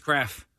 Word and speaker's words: Krapf: Or do you pronounce it Kraff? – No, it's Kraff. – Kraff School Bus, spelled K-R-A-P-F.